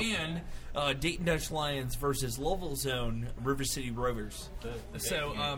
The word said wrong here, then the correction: Louisville: those are did you say Lovell? – Louisville.